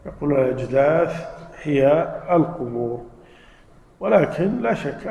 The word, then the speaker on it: الأجداث: فإذا هم من الأجداث إلى ربهم ينسلون. الأجداث هذه تقال بالثاء وبالفاء في قراءة، لكن ما أظن أنها متواترة. الأجداث، وإذا عرفنا أن الأجداث تنطق بالثاء والفاء أيضا نستفيد فائدتين.